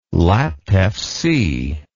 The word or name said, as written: Laptev Sea